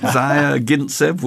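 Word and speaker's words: Zvyagintsev: It's obviously his surname's fairly hard to pronounce. Zyagintsev? – That's about right. Zyagintsev will do.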